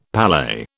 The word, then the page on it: palais: British English